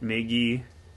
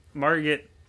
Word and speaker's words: Margot: was it Maggie? – Margot Robbie. – I think she did a good job. Margot.